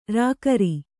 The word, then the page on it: ಱಕಾರ: ṛkāra